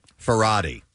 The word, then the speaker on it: Farhadi: The phone line is my Penelope says he's a Farty on the phone. I think I'd have to go. – Farhadi. – Oh. – I think is how you pronounce it.